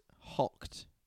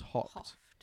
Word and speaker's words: houghed: Hocked. But it's spelt... – Like ham hock. – H-O-U-G-H-E-D. – Like hort. – Like... – Hawked. – Like coughed. – Coughed. – Huh. But I think it's pronounced hocked.